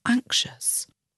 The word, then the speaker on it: anxious: The noun anxiety, A-N-X-I-E-T-Y, and the adjective anxious, A-N-X-I-O-U-S, can be difficult for English language learners. The trick is that the X is pronounced as a Z in anxiety, and the X is pronounced as a SH, sh, sound in the word anxious, anxiety and anxious.